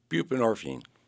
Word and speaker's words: buprenorphine: Buprenorphine. – Buprenorphine.